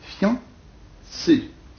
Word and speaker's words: fiancé: Prononciation et lecture, conversation et lecture, c'est fiancé, c'est-à-dire deux syllabes. Bon, il ne faut pas prononcer un i, mais on prononce un iode, fian, une seule syllabe, voyons.